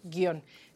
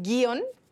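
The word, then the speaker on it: guion: Eso quiere decir que tenemos ahí un diptongo, en realidad, porque la que suena es la I y la O. Entonces se mantienen en la misma sílaba, es un monosílabo, se pronuncia así, guión. Si pronunciáramos guión, en ese caso sí tendría que llevar la tilde y en la I, no en la O.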